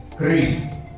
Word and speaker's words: kri: Karma lo conosciamo per destino, ma karma ha una radice etimologica che deriva dalle due sillabe kri, KR, però con, diciamo, con, una pronuncia che assomiglia a una ri.